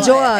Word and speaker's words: Joan: Não, é Joan. – É a pronúncia certa.